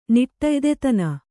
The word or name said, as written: ನಿಟ್ಟೆಯ್ದೆತನ